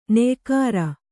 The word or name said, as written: ನೆಯಿಕಾರ